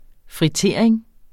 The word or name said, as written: fritering